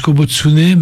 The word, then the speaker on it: Kobutsune: Kobutsune, donc, alors je prononce Kobutsune, mais il faut préciser l'orthographe. – Alors c'est K-O-B-U-T-S-U-N-E.